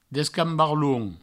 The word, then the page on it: descambarlons: Provençal; descambarloun